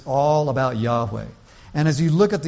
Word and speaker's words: Yahweh: Some people pronounce it as Jehovah. But it is probably more correct to say Yahweh.